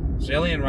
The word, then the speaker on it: Zillion: Speaking of Zillion Opal, Zillion rhymes with alien.